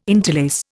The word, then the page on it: interlace: Indian